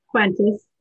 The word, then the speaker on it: Kwentus: Kwentus, yeah.